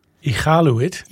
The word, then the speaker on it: Iqaluit: Because if you pronounce iqaluit with a U after the Q, it changes the meaning to a very distasteful one.